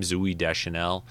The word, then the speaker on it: Zooey Deschanel: Zooey Deschanel.